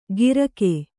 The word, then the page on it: ಗಿರಕೆ: Kannada